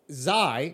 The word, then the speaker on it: Xi: You said Kai. – Bert Massey, the son of Bert Massey. No. Okay, so Zai is spelled X-I.